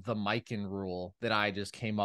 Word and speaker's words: Mikan: George Mikan. – You're going with an alternate pronunciation, sort of a pecan type deal.